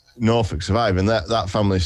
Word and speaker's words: Norfolk: Nor- Norfolk's. Norfolk's.